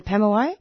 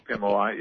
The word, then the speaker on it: Pemulwuy: Yeah, Pemulwuy. – Pemulwuy, yes.